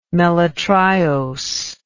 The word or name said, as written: melitriose